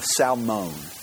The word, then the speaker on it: Salmon: In Matthew chapter 1, verse 4, 5, Rahab marries a guy by the name of Salmon. Okay? And it's spelled S-A-L-M-O-N, Salmon, all right, Salmon.